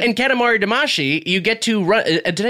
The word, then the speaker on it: Katamari Damacy: Katamari Damacy. – Katamari Damacy. – But I hear them saying like a she sound. But I guess that's just a slightly different pronunciation. – Yeah, it's a different phone.